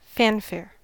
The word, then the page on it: fanfare: American English